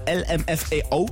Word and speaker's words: LMFAO: Und jetzt nochmal LMFAO.